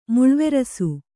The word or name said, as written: ಮುಳ್ವೆರಸು